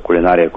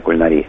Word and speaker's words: кулинария: Примеров сотни, но самый такой расхожий – это кулинария, кулинария. Кулинария вытеснила фактически старое ударение кулинария.